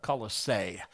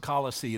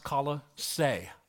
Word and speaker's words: Colossae: That's how you pronounce the name. If you've ever wondered, well, some people can pronounce it Colossae. They have no knowledge of how it's supposed to be pronounced. That's why, and they should have been smart enough to look it up. They didn't. It's pronounced Colossae, like you're going to say Colorado, but you Colossae.